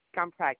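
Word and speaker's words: Gumprecht: Well, my name is Wendy, but it's going to be under Jane Scumpert. I'm calling on behalf of my father. – And what's the last name? – Scumpert, G-U-M, he is in Peter, R-E-C-H-T.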